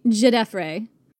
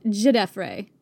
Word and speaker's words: Djedefre: Djedefre. – There's a D at the front. – A silent D, correct.